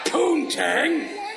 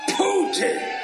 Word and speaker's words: poutine: It's not poontang. It's pronounced Putin. – That's right. We're in Canada. The French-Canadian pronunciation is Putin.